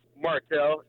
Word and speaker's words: Martell: Martel, M-A-R-T-E-L-L, Martel.